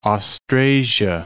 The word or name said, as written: Ostrasia